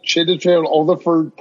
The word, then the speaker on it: Chiwetel Ejiofor: You can't say Doug Liman, but you could say that Chiwetel Ejiofor perfect.